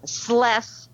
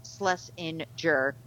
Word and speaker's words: Schlesinger: which I think is Schles, rhymes with less, Schlesinger, CBS News business analyst, host of Jill on Money, and Andy's pal.